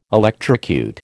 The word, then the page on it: electrocute: American English